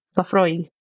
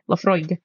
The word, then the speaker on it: Laphroaig: You do pronounce the G. Laphroaig. Although I suppose when you say it, it does sound like it doesn't have the G, but yeah, Laphroaig.